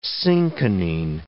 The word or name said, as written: cinchonine